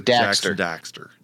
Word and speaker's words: Daxter: Well, it's an A, so Daxter.